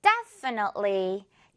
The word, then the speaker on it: definitely: 자, 첫 번째 단어, 전적으로, definitely, 여기서 틀리로 끝내지 않게 조심해야 하는 거 알죠?